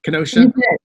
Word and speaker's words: Kenosha: Hopefully I enunciated and pronounced the words correctly, Kenosha.